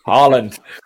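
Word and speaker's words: Haaland: Yeah, I thought, um, do you just want to correct uh, Tony on his pronunciation of of Horland? because he he got the pronunciation incorrect. I just want to. – I you did pretty well, mate. Holland, Holland. You, don't have all those letters.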